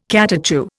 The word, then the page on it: catechu: Indian